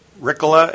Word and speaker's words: Rikala: My full name is Ward Daniel Rickla, and the last name is spelled R-I-K-A-L-A.